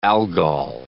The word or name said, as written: Algol